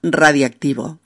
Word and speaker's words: radiactivo: sino que cae la O de radio y la palabra se vuelve más cómoda de pronunciar al convertirse en radioactivo. Ay, que me equivoco yo. Al convertirse en radiactivo.